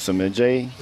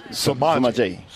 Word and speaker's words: Samaje: Is it Samaje? – No, it's Samaje.